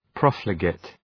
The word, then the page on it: profligate: ‘prɒfləgıt